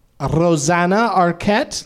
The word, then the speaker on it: Rosanna Arquette: Rosanna Arquette.